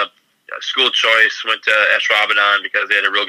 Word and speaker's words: Ashwaubenon: How do you pronounce it? – Most people don't. It's Ashwaubenon. – Ashwaubenon.